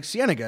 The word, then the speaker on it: Cienega: Cienega Valley. Uh, it's, it's like Cienega. It's like La Cienega.